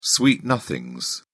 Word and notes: sweet nothings: British English

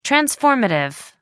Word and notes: transformative: American English

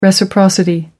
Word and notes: reciprocity: American English

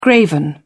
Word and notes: graven image: British English

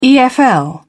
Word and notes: efl: British English

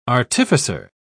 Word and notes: artificer: British English

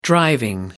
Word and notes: driving: American English

